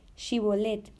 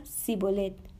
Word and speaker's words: Shibolet: Si él respondía no, entonces le decía, ahora pues di Shibolet. Y él decía Sibolet, porque no podía pronunciarlo correctamente.